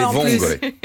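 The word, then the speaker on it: vongole: Mais vous avez l'accent tonique est sur la première syllabe. Oui, allez vangolais.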